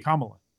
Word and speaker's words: Kamala: Kamala. – No. – Kamala. It's Kamala. – Kamala.